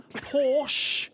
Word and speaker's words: Porsche: Porsche is a rather nice word. – Hang on, say that again. – Porsche. – Porsche. Porsche. I don't like Porsche. – That is the correct pronunciation of the automobile, isn't it? – Luke doesn't like Porsche.